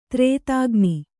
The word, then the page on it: ತ್ರೇತಾಗ್ನಿ: kannada